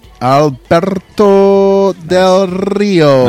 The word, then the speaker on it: Alberto Del Rio: Say it right. – Alberto Del Rio. – Nice. There we go.